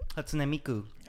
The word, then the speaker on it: Hatsune Miku: Hatsune Miku.